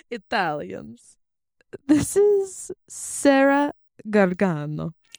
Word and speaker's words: Gargano: And I think you said it wrong. It's Sarah Gargano. – Gargano.